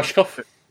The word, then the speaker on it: Rushkoff: Rushkoff.